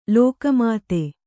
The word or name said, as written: ಲೋಕಮಾತೆ